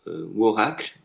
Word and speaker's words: Worakls: Et donc, c'est une collab aussi avec un artiste qui s'appelle Worakls. Alors, je ne sais pas si je prononce bien d'ailleurs. – Oui. Exactement, ouais, c'est ça.